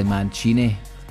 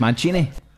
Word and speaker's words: Mancini: Now, we've just discovered there that it's actually Mancini. Now we've got three Italians essentially in here. We've got two Scots, me and Craigie, but I've been told it's Mancini. Proper way to say it.